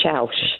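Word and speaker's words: Chãos: It's pronounced chaos as far as I'm concerned. But it's spelled chaos. It is literally spelled chaos. There's chaos in Portugal. Shaush. It's pronounced Shaush, like mouse.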